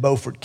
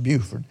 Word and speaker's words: Beaufort: That's the first city in the nation that was named after George Washington, in Washington, North Carolina, down in Beaufort County. Now, those of you from South Carolina, you might think that's pronounced Buford, but it's Beaufort, North Carolina.